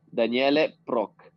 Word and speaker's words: Daniele Proch: Daniele Proch.